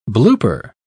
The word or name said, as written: blooper